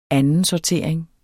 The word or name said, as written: andensortering